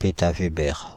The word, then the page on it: pétaweber: /pe.ta.ve.bɛʁ/